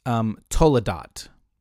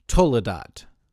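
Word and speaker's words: toledot (תולדות): The word generations in Hebrew, in my really bad, crappy American idea of how to speak ancient Hebrew, is pronounced toledot. Everyone say toledot. Yes, yes, I hear. Yes, you got it. You nailed it.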